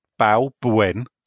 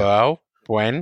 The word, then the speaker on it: Balbuena: By the way, what's so hard about pronouncing Balbuena? Balbuena. He's actually spelled it out for you. – Balbuena.